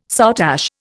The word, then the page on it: soutache: Indian